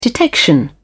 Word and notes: detection: British English

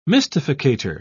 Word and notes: mystificator: British English

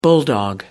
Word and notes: bulldog: American English

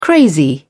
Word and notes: crazy: British English

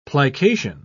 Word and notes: plication: British English

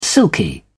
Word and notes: silky: British English

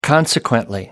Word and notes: consequently: American English

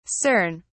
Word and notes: cern: British English

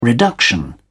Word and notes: reduction: British English

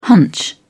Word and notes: hunch: British English

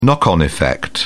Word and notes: knock-on effect: British English

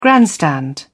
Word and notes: grandstand: British English

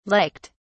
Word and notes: liked: British English

